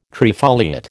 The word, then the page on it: trifoliate: American English